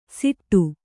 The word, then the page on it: ಸಿಟ್ಟು: siṭṭu